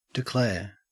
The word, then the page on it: declare: Australian English